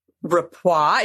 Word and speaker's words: rapport: rapport. Is that how you say it? Rapport? – Yes.